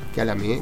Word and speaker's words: Kyalami: A ver, quiero ver si lo dije bien, porque Marcelito nos pasa, ¿cómo se llama? Se llamaba el circuito de Sudáfrica, Kyalami. ¿Bien? – Sí, bien. – Sí. – Kyalami, gracias Marcelo por el aporte.